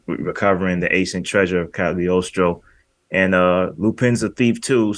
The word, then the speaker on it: Cagliostro: Yeah, Cagliostro.